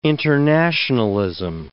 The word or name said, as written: internationalism